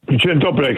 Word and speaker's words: Dzień dobry: Dzień dobry.